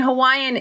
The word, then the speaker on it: Hawaii: Like you mentioned, ohana means family, but I mean, Hawaiian is actually a language, right? I noticed that you actually pronounced Hawaii, Hawaii, which is the correct Hawaii pronunciation from Hawaiians. That's how they pronounce it, right?